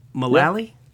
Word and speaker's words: Mullally: Am I saying that right? Mullally? Yeah.